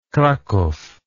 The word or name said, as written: krakow